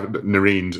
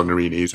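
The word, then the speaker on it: nerines: So you have nereens or nerenes, however you want to pronounce it, lovely bulbs for autumn, but also lots of prairie kind of North American plants, things like Monarda and Echinacea, Persicaria as well.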